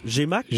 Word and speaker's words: GMAQ: On le prononce? GMAQ? – GMAQ. – GMAQ, parfait.